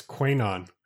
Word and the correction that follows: QAnon: I don't know. Two letters of capital. – QAnon. Don't worry.